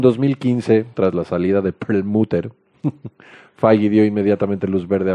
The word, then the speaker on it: Perlmutter: Ike Perlmutter. ¿Lo dije bien? – Perlmutter.